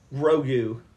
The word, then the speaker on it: Grogu: Grogu is his name. – Spell it. To the best of your abilities. – G-R-U-U-G-O.